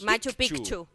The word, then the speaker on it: Machu Picchu: Machu Picchu.